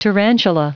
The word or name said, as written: tarantula